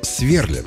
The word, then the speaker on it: сверлят: Представьте, в этом случае они пока еще «сверлят». С ударением на первый слог.